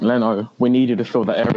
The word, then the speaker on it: Leno: It's Leno. Leno. I mean, it's a four-letter word. It's really not that hard to pronounce.